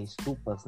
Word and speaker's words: estupas: E aí, né, posteriormente, nessas estudas, como eles chamavam, eu não sei se pronuncia estudas mesmo, é estupas, né, quer dizer.